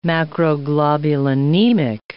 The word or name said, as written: macroglobulinemic